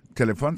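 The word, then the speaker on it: telephone: Téléphone, c'est comme ça on dit téléphone en anglais. Je vais répéter encore pour nous. Téléphone. On a remarqué que l'accent tonique l'a tombé sous premier syllabe là.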